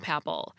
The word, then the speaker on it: papal: Except it should have been pronounced papal, not papal. It's a papal bull. Because of the spelling, P-A-P-A-L, I suspected that papal was related to the word father, and I was right.